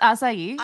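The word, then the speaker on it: açaí: I think it's pronounced acai.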